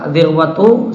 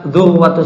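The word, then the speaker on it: dhirwatu (ذروة): Dhirwa bacanya dhalnya di kasrah. Dhir. Boleh juga dhamma. Dhalnya di koma. Dhu. Boleh juga dibaca fathah. Dhar. Bisa dhirwatu sanamihi, bisa dhurwatu sanami, bisa dharwatu sanamihi.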